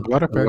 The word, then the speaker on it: Glória Pérez: A Glória Pérez, cara, eu tô falando certo? Glória Pérez. – Glória Pérez é.